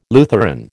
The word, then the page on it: Lutheran: American English